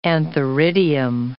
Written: antheridium

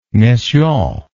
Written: messiaen

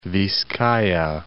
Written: Vizcaya

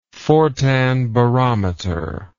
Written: fortin barometer

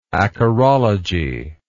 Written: acarology

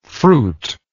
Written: fruitlike

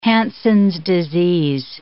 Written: Hansen's disease

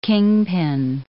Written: kingpin

